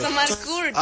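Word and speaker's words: Omar Courtz: ¿Cómo se dice eso? Es que ahorita ando funando. Ahorita no te me atraveses. – Es Omar Kurtz.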